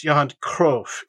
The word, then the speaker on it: Johan Cruyff: "Truly, it was an honor to play here at the Johan Cruyff Arena." – Yeah, Johan Cruyff Arena. Marlein says, "Oh, no, the honor is ours." And then in true Dutch fashion, "ours completely, but it's pronounced Johan Cruyff.